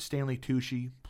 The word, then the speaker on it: Stanley Tucci: I just think even those little bitty roles – like Liev and and Stanley Tucci playing the mm-hmm. attorney. – It was spot on everything that who directed it. – I am saying his name right. Stanley Tucci. – Stanley Tucci. – Okay.